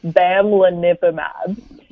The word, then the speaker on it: bamlanivimab: And then the other one, and this is a really hard one to pronounce, I'm going to say it slowly, it's bamlanivimab.